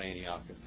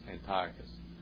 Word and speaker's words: Antiochus: Antiochus IV, Epiphanes, not Antiochus, Antiochus. I got in the habit of saying Antiochus a long time ago, and I realized I was mispronouncing it.